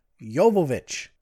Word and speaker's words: Jovovich: And I just learned that I've been pronouncing Mila Jovovich's name wrong the whole time. Thanks to her website, it says how to pronounce her name, Jovovich.